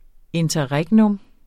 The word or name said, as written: interregnum